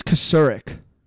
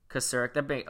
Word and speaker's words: Kocurek: Chris Kokorek? That's a D-line coach. Is that how you say his last name? – I think it's Kocurek. – Kocurek. Okay, so K-O-C-U-R-E-K.